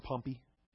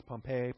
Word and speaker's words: Pompey: Some people call him Pompey. I've heard it pronounced different ways. Pompey, Pompey.